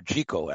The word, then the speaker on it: Jekyll: Robert Louis Stevenson's, of course, really dealt with this theme directly in Jekyll and, you know, the Strange Case of Dr. Jekyll, actually, is the way Stevenson pronounced it, and Mr. Hyde.